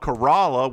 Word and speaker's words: Corolla: We also have an island called Kerala, which is spelled Corolla, that is full of wild horses.